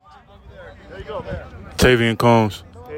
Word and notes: Tavian Combs: TAY vee in COMBS

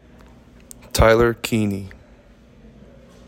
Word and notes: Tyler Kiehne: TIE ler KEY KNEE

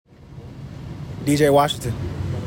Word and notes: D.J. Washington: DEE JAY WASH ing ton

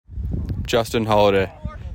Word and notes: Justin Holaday: JUST ihn HOL a DAY; just like Holiday

